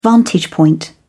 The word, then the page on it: vantage point: British English